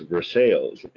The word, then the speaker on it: Versailles: And we have a town that's named after the palace at Versailles, but it's pronounced Versailles in Kentucky.